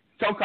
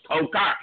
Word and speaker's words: Khokhar: What is it, Komar? – Omar Khokhar, you got it. – Khokhar, I was close. Khokhar.